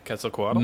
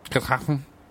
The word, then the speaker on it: Quetzalcoatl: Quetzalcoatl? – Mm-hmm. – Uh-huh. – Quetzalcoatl. – Quetzalcoatl. – Quetzalcoatl. – All the X's in that language are pronounced sh.